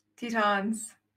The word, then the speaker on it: Tetons: He loves Arches and Grand, how do you say that? – Tetons. – Grand Tet with an S? – Mm-hmm. Yeah, the Grand Tetons.